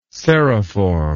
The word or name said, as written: serriform